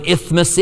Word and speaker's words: isthmus: And if you don't know what an isthmus is, I'm not saying it wrong. That's how you say it.